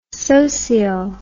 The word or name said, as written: socio